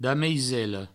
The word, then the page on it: damaisela: Provençal; dameisello; damisello